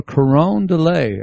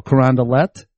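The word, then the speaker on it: Carondelet: I tried to say, "Oh, I'm looking for Caron de Lay," and they go, "Caron de Lait." They pronounce the T's.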